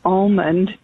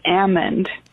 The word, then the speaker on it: almond: The L is silent. So it's not even really almond. It's almond. So just thought I'd update you guys on that.